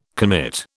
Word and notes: commit: British English

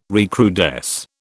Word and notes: recrudesce: British English

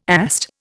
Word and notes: AST: Indian

